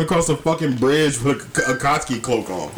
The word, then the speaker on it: Akatsuki: Kotsky? – Akotsky. – Akotsky. – Akotsky. – And it doesn't look like how it's spelled. That's the point. Akotsky? – Because it's Akatsuki.